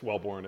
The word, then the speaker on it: Welbourn: Yeah, it's Welbourn. It looks just like it sounds.